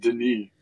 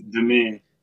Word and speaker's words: Denis: And obviously, he did Dennis, talking about Dennis. Dennis Villeneuve? – It's pronounced Denis. – Novi? – No, it isn't. First name it's not isn't Dennis. Like Dennis. – Oh. – It's Denis. – It's Denis.